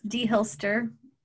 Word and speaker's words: deHilster: It's deHilster.